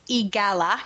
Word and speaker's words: EAGALA: I'm going to call that EGALA because I'm lazy. – That's how they pronounce it, actually.